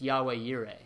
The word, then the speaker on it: Yahweh Yireh: And so Jehovah is simply a word that's morphed, basically, from Yahweh. And so if you ever hear that, Jehovah Jireh. Well, originally, it's Yahweh Yireh. That's how you say it, the Lord, our provider.